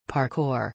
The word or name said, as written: parkour